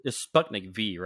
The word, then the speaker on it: Sputnik V: You guys keep saying it wrong. You know, it's Sputnik V, right? – Whatever. – No, it's Sputnik 5. Sputnik 5. – No, it's V for vodka.